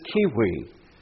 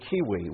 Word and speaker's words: civi: Our word civilization comes from the Latin word for city, which is kiwi. We have everything from civil to civilization to civics, all based on that Latin word kiwi, which is spelled C-I-V-I.